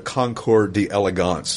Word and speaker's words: Concours d'Elegance: It's some kind of, people don't always know what a Concours d'Elegance, see, I said it right that time, is, and it's just a fancy car show.